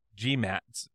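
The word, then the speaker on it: Maté's: So, like, it very easily, like, oh, yeah, I know that I'm, like, by, you know, Gabor G-Mott's definition. – Accent on the E there.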